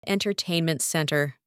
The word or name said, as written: entertainment centre